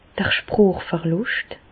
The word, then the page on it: d'r Sprochverlùscht: Alsatian; Bas-Rhin; Reichshoffen